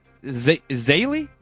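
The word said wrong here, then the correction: Zeile: Is that how you say it? – Zeal. – Todd Zeal? Sorry.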